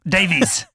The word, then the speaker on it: Davies: Where we talked to Emily Dolan Davis. That's how I like to say her name. – Pretty sure you just pronounced it wrong. – Davies.